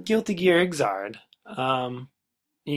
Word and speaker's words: Xrd: that's how you pronounce that. XRD. But if you look at the katakana, it's Iguzado. E starts with, so Xrd.